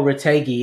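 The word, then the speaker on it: Retegui: And also, if I butcher this, is it Retegi? – Oh, Retegi, yeah.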